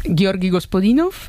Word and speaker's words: Georgi Gospodinov: Georgi Gospodinov, sí.